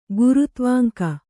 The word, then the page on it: ಗುರುತ್ವಾಂಕ: gurutvāŋka